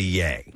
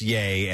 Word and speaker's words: Ye: Every time you pronounce ye as ye, I think someone please tell them how to pronounce it. – Is it yay? – It's apparently yay. – Okay. – Like ye Kanye. Is pronounced yay as in Kanye, not ye.